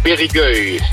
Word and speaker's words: Périgueux: Je zit nu in het dorp Périgueux. Zeg ik dat goed? – Ja, Périgueux. – Périgueux, ja. Oké.